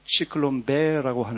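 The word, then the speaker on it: Zyklon B: 유대인들을 가스실에 몰아넣고 그때 당시에 살충제로 사용하던 지글론 B 독가스, 이제 독일어 발음으로는 지글론 B라고 하는데, 1920년 초에 독일에서 쓰던 살충제의 상표명입니다.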